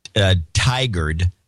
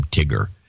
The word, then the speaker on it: Tigard: Portlandia, actually, he's in Tigard, which I've been pronouncing Tigard. And so he wrote a note in to correct me, because it's kind of, I guess, humiliating to be from Tigger.